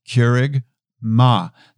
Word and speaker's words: kerygma: Remember, and I've said this before, if you can't remember kerygma, then you can certainly remember Keurig, the coffee maker, Keurig. You can say it right now, say Keurig. Now say Ma, that's our mother, she's with us praying, Ma. Now say it all together, Keurig, Ma.